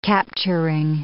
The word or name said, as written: capturing